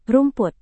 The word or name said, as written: rumput